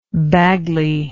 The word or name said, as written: bagley